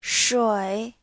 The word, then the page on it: shui: shui3